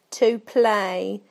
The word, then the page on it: to play: British English